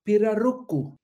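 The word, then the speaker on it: pirarucu: Arapaima gigas, eller i folkmun pirarucco. Lite aggressivt ska man uttala det, har jag läst mig till.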